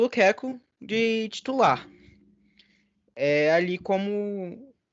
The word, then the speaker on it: Lukaku: Na verdade, eu, eu que sou um maluco aqui de pronúncia, até peguei o jeito certo, é Lukaku, de se pronunciar.